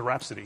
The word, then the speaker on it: rhapsody: What is a rhapsody? – Rhapsody. The H is silent.